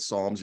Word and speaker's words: Psalms: And he said, well, the P is silent at Psalms.